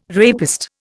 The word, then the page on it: rapist: Indian